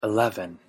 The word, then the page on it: eleven: English